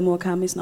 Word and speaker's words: Murakamis: Er det sådan, man udtaler Murakamis navn i virkeligheden? – Ja.